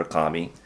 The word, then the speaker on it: kami: And they thought that in addition to the standard Shinto belief that everything was a kami or a god, the head of the Kankō-kyō said, yeah, the kami and the bodhisattvas are the same. You can call them bodhisattvas or kami. – Kami, K-A-M-I. – K-A-M-I.